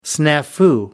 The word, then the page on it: snafu: American English